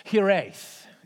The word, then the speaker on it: Hiraeth: Hiraeth, is how I'm going to pronounce it. Hiraeth is the word, H-I-R-A-E-T-H, and it's a Welsh term that describes a homesickness, a longing for a home that has been lost or that never was.